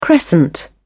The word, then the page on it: crescent: British English